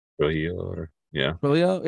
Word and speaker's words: Trujillo: Trujillo? – Yeah.